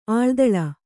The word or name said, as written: ಆಳ್ದಳ